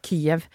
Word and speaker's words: Kyiv: Hva var ditt inntrykk av Kyiv, som jeg nå har lært at jeg skal uttale det? – Kyiv.